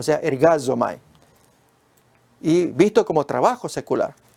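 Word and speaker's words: ergazomai (ἐργάζομαι): Pues miremos ahora, el verbo es ergazomai, se pronuncia así, yo trabajo, visto como obra secular en los otros textos del Nuevo Testamento.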